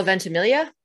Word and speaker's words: Ventimiglia: Milo Ventimiglia, however you say his name. – oh, Milo Ventimiglia. There we go.